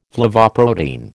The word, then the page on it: flavoprotein: American English